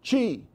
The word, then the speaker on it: Qi: It's called QI, and you would think you would pronounce it QI, but you don't. You pronounce it QI.